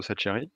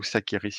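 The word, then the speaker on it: Sacheri: qui s'appelle Eduardo Saccheri, euh, ou Saccheri si vous le prononcez à l'italienne, mais là ce sera tout un débat.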